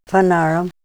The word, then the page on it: fanaram: Ninilchik Russian